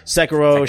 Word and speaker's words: Sekiro: Is Sekiro. How you pronounce it? – Sekiro.